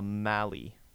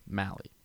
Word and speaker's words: Mallee: Or Malleys. Am I saying that right? – I have no idea what word you're even trying to say. – A Mallee. – Mallee. – Mallee. – Cool. – Mallee. M-A-L-L-E-E.